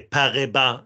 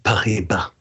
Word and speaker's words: Paribas: Var det bättre uttal den här gången Tim, eh, känner du eller? – Jag skulle säga att det var en, eh, en femma. – Fem av fem. Mm, Pariba.